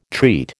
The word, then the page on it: treat: American English